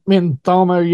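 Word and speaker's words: Mngxitama: Andile Mutsama. Yeah, you got to put a click in there. You to say it right.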